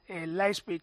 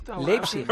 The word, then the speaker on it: Leipzig: Mallorca, Leipzig, ¿lo pronuncio mejor que ayer? – No, igual. No, muy bien. – Leipzig.